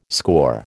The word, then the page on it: score: American English